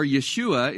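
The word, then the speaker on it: Yeshua (ישוע): Joshua, or Yeshua, is the way you'd pronounce it in Hebrew.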